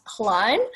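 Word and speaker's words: Hlaing: My surname is pronounced Klein, but it is spelt, it's a bit tricky, it's H-L-A-I-N-G.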